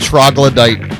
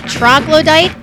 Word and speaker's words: Troglodyte: Troglodyte. – Troglodyte.